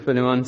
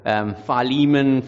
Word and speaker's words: Philemon: but probably more with my pronunciation of the book. Philemon, Philemon, I'm a South African man. I'm going to say it the way I was taught. So anyway, just forgive me if you prefer Philemon.